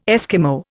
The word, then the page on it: Eskimo: Indian